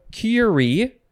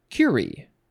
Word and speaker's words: curie: V-I-R-I-L-E. Okay, next. We have curie or curie. Emphasize either syllable. It is a noun from 1910. I think I will say curie. Yeah, emphasis on the first one. That's how I like it.